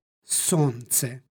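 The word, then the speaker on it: solnce: U sledećoj reči dolazi do uprošćavanja suglasničke grupe i izgovaramo, kao i u srpskom, bez l, sonce.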